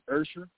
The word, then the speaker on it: Usher: By way of Atlanta, the correct pronunciation is Usher.